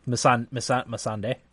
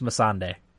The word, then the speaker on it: Missandei: No, Missandei. – Missandra. Something like that. – Missandei. – Miss Sandy. – I think it's Missandei. – Okay. – That's how I pronounce it.